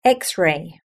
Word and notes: xray: British English

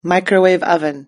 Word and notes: microwave oven: British English

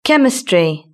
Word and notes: chemistry: British English

